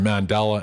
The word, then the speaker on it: Mandala: Before that, from Toronto, Mandala, from the Soul Crusade album, and that was World of Love, doing a good job there, Mandala, and that's spelled M-A-N-D-A-L-A, in case you're thinking of Mandela.